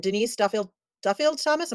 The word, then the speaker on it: Denise Duffield Thomas: And Denise Duffield Thomas, am I saying that right? – Uh-huh.